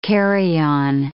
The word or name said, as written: carry-on